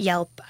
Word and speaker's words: Yelp: And we're pretty excited because when people ask about Yelp, I always say it rhymes with help.